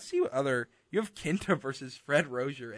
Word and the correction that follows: Rosser: a.k.a. Darren Young. – Fred Rosser. – Rosser?